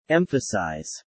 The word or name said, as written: emphasize